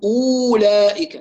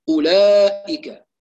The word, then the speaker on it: أولئك: هذه الواو التي وضع أئمة الضبط فوقها هذه الدائرة الصفر المستدير تدل على أن الحرف يثبت رسما فقط لا ينطق، لا نقول أولئك، لا، ننتقل من الهمزة إلى اللام مباشرة، أولئك.